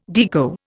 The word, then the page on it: Deco: Indian